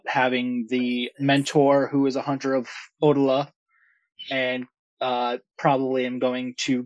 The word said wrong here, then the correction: Odila: From Drew in chat, it's usually pronounced Odila.